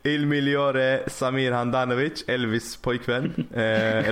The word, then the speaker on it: Migliore: Var det Migliore? Migliore. Migliore. – Det där G, ja, någon G dag kommer det sitta. uttalas, uttalas Gli. Gagliardini.